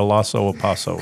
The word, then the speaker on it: Lhasa Apso: I don't know what a Lhasa Apso is.